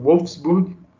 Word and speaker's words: Wolfsburg: Enfim, é, o Santos é, entrou em acordo com o Wolfsburg. Como é que fala? Você que é viajado, já foi para a Alemanha milhares de vezes? É, Wolfsburg. Ah, pensei que tá falar Wolfsburg, uma coisa assim meio Não, alemão.